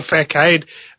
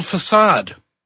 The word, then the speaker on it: facade: And then all of a sudden, the facade drops away. Oh, wait. I pronounced it wrong. It's called facade.